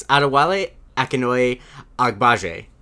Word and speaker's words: Adewale Akinnuoye-Agbaje: Adewale Akinoye Agbaje.